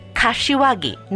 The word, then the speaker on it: Kashiwagi: It's Kashiwagi, not Kawasaki. K is in Korea, A as in America, then Singapore, Hong Kong, India, Washington, America, Greece, and India.